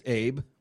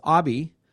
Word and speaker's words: Abe: Now, it's spelled Abe, not Honest Abe. Well, we hope he's honest. It's Abe.